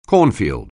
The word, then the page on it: cornfield: anglais